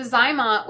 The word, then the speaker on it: ZyMōt: And then he also used the, is it Zymot? Is that Zymot device? What is that? – Yeah. So the Zymot, which is spelled Z-Y-M-O-T.